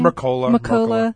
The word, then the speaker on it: Mercola: Interestingly enough, Dr. Mercola, is that how you pronounce his last name? – Mercola.